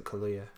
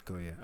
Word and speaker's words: Kaluuya: We saw that Daniel Kaluuya, how you say his name? – I pronounce it Kaluuya. – Kaluuya, all right.